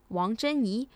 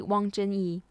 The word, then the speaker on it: Wang Zhenyi: Her name, pronounced in Mandarin, is Wang Zhenyi. So if we pronounce it easier in English, it'll be Wang Zhenyi.